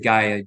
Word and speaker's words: Gaia: I pronounce it Gaia, G-A-I-A, Gaia.